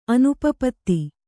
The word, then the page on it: ಅನುಪಪತ್ತಿ: anupapatti